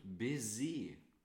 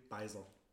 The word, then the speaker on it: Baiser: Coffee Biscuits und Baiser, wie wir das als, äh, wie wir das als Franzosen so schön aussprechen. – Oder wir hier aus, aus Aschersleben, Beiser.